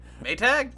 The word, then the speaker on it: Maytag: So if we're going to name drop T-Mobile and Netflix, we can't name drop Magtay? – Maytag? – Or Maytag.